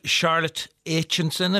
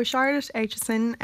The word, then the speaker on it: Charlotte Aitchison: Tell me a little bit more about Charlotte Aitchison, if that's how I say her name correctly. – Yeah, that is. You've got it spot on. So Charlotte Aitchison, Charlie XCX, she is a Cambridge-born singer, songwriter, producer and music video director.